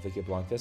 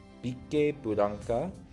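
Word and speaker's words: Vickeblanka: And by the way, for our listeners, just on the pronunciation of Vicky Blanca, so in Japanese, the katakana is Bike Blanca. And so, just, I guess, when we say in English, we'll just say Vicky Blanca.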